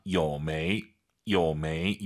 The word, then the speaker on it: 有没有: 有没有? 삼성이성 아아 요메이 요메이 이 발음 따라하시면서 요메이요 이렇게 발음하세요. 요메이요 아니에요 여러분. 많은 쓰는 발음인 만큼 이건 박제해서 우리가 외워야 됩니다. 요메이요 요메이요 이런 식으로.